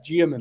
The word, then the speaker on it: Diarmuid: It's Diarmuid. Two syllables. Yeah. So the spelling doesn't help. – You replace the D with a J and you're pretty good, yeah? Diarmuid. – Yeah, basically.